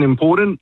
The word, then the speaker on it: important: In it's no D in important. It's important.